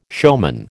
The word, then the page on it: showman: American English